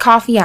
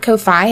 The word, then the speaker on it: Ko-fi: And some folks call it coffee. I call it Ko-Fi, and that's spelled K-O, like knockout, hyphen F-I.